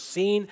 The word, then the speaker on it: Sin: The original word in Hebrew is pronounced sin. It's very similar to the word Sinai.